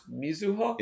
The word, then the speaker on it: Mizuho: So what does, I have a question about, you said Mizuho, mm-hmm. but in Japanese, I think it's pronounced Mizuho. – Yeah, that would be closer.